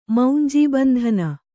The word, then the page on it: ಮೌಂಜೀಬಂಧನ: maunjī bandhana